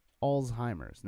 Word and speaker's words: Alzheimer's: Alzheimer's? – It's what it is. That's definitely not how you pronounce it, but fine. – Alzheimer's, no T.